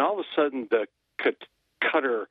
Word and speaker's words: Qatar: And I can never remember, because some people pronounce it like gutter. – Cutter? – With a G, but cutter. – It's cutter.